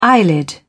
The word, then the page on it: eyelid: British English